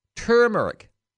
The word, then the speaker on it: turmeric: But just since someone said that, the other word that I've just since come to understand, it's pronounced differently than, it's spelt differently than it's pronounced. Turmeric. T-U-R-M-E-I-C. Turmeric.